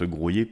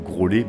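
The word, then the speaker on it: Graulhet: Ben, j'aime bien expliquer que voilà, Groyer, en fait, ça se prononce Groyer parce que dans le nom de cette commune, il y a les lettres LH qui se prononce lieu, c'est Graouliet en occitan. Et justement, cette particularité vient de l'occitan et a été conservée surtout. Peu de personnes, j'espère, ne prononcent Grolet, même si ça doit exister.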